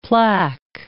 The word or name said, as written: plaque